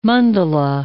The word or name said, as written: mandala